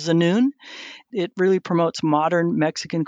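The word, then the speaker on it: Tzunun: So Zanun, it's T-Z-U-N-U-N. So the T is silent, Zanun.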